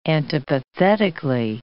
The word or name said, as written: antipathetically